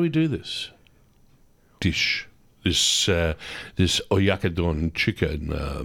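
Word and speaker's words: Oyakodon: Oyakudon.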